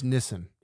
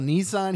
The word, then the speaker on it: Nissan: He's saying, it's pronounced Nissan. – Yeah. Nissan. – It's called Nissan here in America. – Yeah. – But in the rest of the world, it's Nissan.